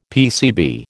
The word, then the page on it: PCB: American English